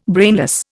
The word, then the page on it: brainless: Indian